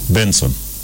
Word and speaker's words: BNXN: We are listening to we're listening to a singer from a Nigerian singer, and he spells his name as BNXN. – BNXN. – But do you know what that is pronounced? – No. – Benson.